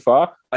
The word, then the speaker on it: pho: Do I alienate the majority of people that don't know it's pronounced pho and sound like an asshole? – Totally.